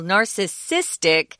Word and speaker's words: narcissistic: There's narcissism, which is a noun, and there's narcissistic. We change the emphasis to a different syllable, narcissistic, um, which is an adjective, and you'll hear that quite a bit too.